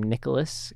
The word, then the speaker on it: Nicolas: I also write music under the name Nicholas KZ.